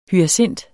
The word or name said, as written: HYACINTEN